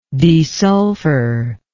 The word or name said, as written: desulfur